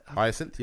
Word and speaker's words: Hyacinth: Hyacinth, yep.